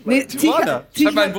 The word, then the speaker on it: Tijan: Tijan haben wir doch gesagt. Wir haben Tijan gesagt. – Tichan. – Entschuldigung, achso, Entschuldigung, wir müssen es aussprechen wie das deutsche Töreton.